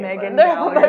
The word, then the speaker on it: Megan Gallagher: Megan Gallagher.